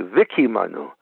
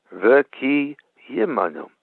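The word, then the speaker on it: Vikimanu (וקיימנו): They say Vikimanu. That's not correct. It's Vikimanu. There's a yud there. Vikimanu.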